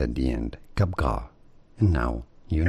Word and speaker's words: Capgras: Capgras is how it is said in French. In French, the S at the end is silent. Now, in English, it's generally referred to as Capgras syndrome. But if you want to be, you know, a little bit better, Capgras, it would what?